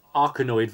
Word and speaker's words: Arkanoid: Arkanoid versus Space Invaders was released on May 17th, 2017.